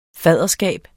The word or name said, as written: fadderskab